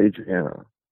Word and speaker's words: Adriana: Adrian? Am I saying that right? From Jason X? Adriana? Was her name Adrian or Adriana? From Jason X. – Adriana. – Yeah, right? Adriana.